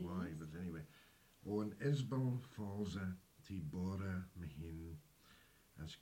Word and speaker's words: Ysbal: Now, Isbel, it's not Isabel. – No. – In Manx, it's Isbel, and it's spelled Y-S-B-A-L.